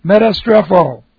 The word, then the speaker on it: metastrepho: S-T-R-E-P-H, and then we have an O, strepho. Okay, this is M-E-T-A, and then S-T-R long E-P-H long O, metastrepho.